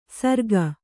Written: ಸರ್ಗ

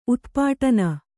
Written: ಉತ್ಪಾಟನ

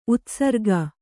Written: ಉತ್ಸರ್ಗ

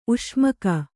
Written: ಉಷ್ಮಕ

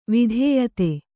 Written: ವಿದ್ಯೋತ

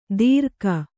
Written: ದೀರ್ಘ

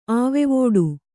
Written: ಆವೆವೋಡು

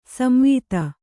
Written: ಸಂವಾದ

